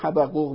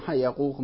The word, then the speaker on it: حبقوق: هر دو میگن، هم حبقوق میگن هم حیقوق میگن.